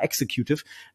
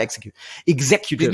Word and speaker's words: Executive: CEO wäre es, wenn wir ein privatwirtschaftliches Unternehmen wären, dann wäre ich tatsächlich der Chief Executive Officer, der in Deutschland fast immer falsch ausgesprochen wird. Leute sagen immer Executive. – Executive, ja. – genau, das heißt Executive. – Wie heißt es richtig? Chief, sag's nochmal bitte. – Chief Executive Officer. Zweizimmig betont auf Exec und eben Gs und nicht X.